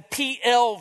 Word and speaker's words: piel: One is known as the PL verb, the PL. Now, if you're interested, you'd spell that P-I-E-L, PL.